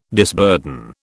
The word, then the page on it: disburden: British English